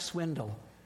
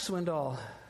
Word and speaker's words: Swindoll: Or as my dad used to say, Chuck Swindle. He never knew he was saying it wrong. Chuck Swindoll.